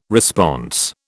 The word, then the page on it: response: British English